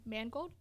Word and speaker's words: Mangold: James, probably going to butcher his name, Mangold? – Sounds right to me.